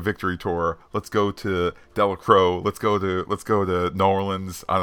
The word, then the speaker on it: Delacroix: As someone who is from New Orleans and fishes in, I'm going to say it wrong here first because he's going to fix it, Delacroix, pretty cool to see that mentioned in an MCU show. Just wanted to help you out with some Louisiana pronunciation. Delacroix is how it's said.